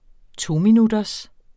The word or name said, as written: tominutters